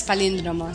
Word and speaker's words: palíndromo: Sí, palindromo, efectivamente, palindromo, con tilde, okay. Lleva tilde, sí, okay. Exacto. Sí, lo mismo que con los números es capicúa, pues sí. Con las letras es palíndromo, exacto.